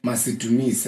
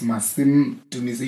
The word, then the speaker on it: Masim Dumise: You know, they'll call me Masim Dumise, and I'm like, nope, it's Masim Dumise. You have to say it like that, the right way, or not say it.